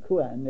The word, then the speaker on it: Couin: I'm not sure how you pronounce it. C-O-U-I-N. The village. – C-O-U-I-N. Kuan, yes.